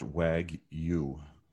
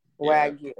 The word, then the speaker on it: Wagyu: This says it's pronounced Wagyu. – Wagyu.